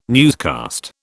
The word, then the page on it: newscast: British English